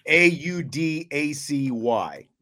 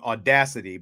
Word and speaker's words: Audacy: Yeah, Audacy is uh, is how it's pronounced. It looks like like when you first see it, it looks like you should be saying Audacity, but then you look at it and it's Right. It's Audacy, A U D A C Y, and you can get all the Notre Dame broadcasts of all the different sports through that app.